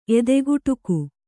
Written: ಎದೆಗುಟುಕು

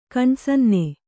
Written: ಕಣ್ಸನ್ನೆ

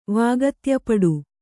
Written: ವಾಗತ್ಯಪಡು

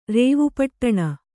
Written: ರೇವುಪಟ್ಟಣ

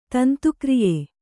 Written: ತಂತುಕ್ರಿಯೆ

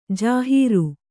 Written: ಜಾಹಿರು

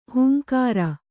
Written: ಹೂಂಕಾರ

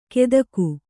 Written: ಕೆದಕು

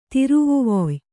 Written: ತಿರುವುವೊಯ್

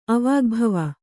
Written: ಅವಾಗ್ಭವ

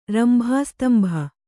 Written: ರಂಭಾಸ್ತಂಭ

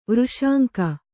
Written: ವೃಷಾಂಕ

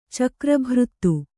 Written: ಚಕ್ರಭೃತ್ತು

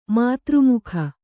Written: ಮಾತೃಮುಖ

